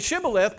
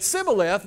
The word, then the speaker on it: shibboleth: And it says that because they could not pronounce the word correctly, then anybody that did that, that couldn't say shibboleth but said sibboleth, they would take them and kill them.